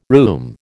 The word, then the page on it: rheum: American English